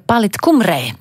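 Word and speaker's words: Plaid Cymru: En dat moet ik dan weer zo uitspreken. Palit Cymru.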